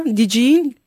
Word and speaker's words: DJing: C'est ça, on prononce comme ça, DJing? – Oui, très bien, très bien.